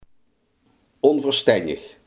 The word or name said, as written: onverstandig